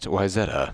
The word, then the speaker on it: Wayzata: For those of you who don't live in the Twin Cities area, it's pronounced Wayzata.